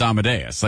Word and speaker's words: Amadeus: Cause if you've ever heard it, you know it's Amadeus.